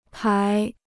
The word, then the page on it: 牌: pái